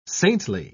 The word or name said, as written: saintly